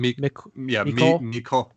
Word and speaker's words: Mecole: Mecole.